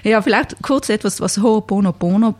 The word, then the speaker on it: Ho'oponopono: Ho'oponopono ist das. – Darf ich das nicht mehr wissen? – Ho'oponopono.